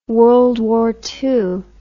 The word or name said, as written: World War II